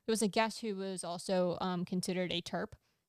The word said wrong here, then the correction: TERF: TERF. – That's what I said. I mispronounced my last letter.